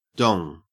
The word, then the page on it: dong: Australian English